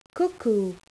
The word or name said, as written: cuckoo